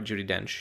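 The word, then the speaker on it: Judi Dench: Judy Dench? – Yeah.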